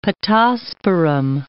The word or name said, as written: pittosporum